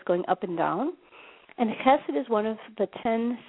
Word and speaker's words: chesed: And we're going to look at the Hebrew translation of charity, which is called chesed. And chesed is spelled C-H-E-S-E-D in the English transliteration.